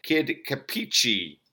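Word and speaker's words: Kid Kapichi: Up next here on the Broken Cassette Player is the band Kid Capicci. I think I'm pronouncing that correctly. That is K-A-P-I-C-H-I, Kid Capicci.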